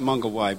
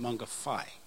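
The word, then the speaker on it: Mangawhai: Well, it looks like Maunga Wai, but it's the Maori pronounce it Maunga Whai, W-H.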